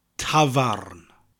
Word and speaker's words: tafarn: Now pub in Welsh is like the old English word tavern, but we pronounce that R, tavarn. Tavarn.